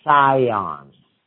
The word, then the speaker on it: Sion: It's called the Priory of Sion. Not Zion. It's spelled S-I-O-N.